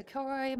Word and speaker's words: Kilroy: It's just at Alexa Kilroy. My last name is spelled K-I-L-R-O-Y, one L, nonviolent, as I like to say.